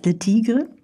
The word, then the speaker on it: Le Tigre: Spricht man die so aus? Le Tigre? – Mhm, ja.